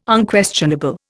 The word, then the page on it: Unquestionable: Indian